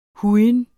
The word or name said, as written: hujen